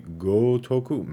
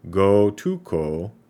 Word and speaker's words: Gotoku: So, uh, particularly the Ryuga Kotaku or excuse me, Gotoku. Man, these names. Ryu ga Gotoku.